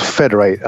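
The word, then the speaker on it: Fedr8: It's a company called Federate, and I'll spell that for you: F-E-D-R and the number 8.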